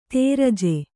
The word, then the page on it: ದರ್ಜೆ: darje